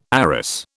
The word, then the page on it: arras: British English